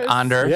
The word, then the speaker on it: Anders: Anders.